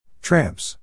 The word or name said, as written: tramps